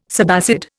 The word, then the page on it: subacid: Indian